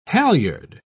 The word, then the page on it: halliard: British English